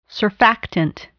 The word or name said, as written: surfactant